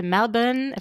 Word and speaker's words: Melbourne: Yeah, I've been to Melbourne, if I say that correctly.